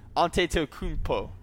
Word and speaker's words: Antetokounmpo: Well, we have Giannis Antetokounmpo. – Yeah, that's actually Jackson, you actually still screwed it up. Antetokounmpo. That's how you say it.